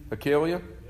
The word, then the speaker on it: Hilkiah: Hilkiah.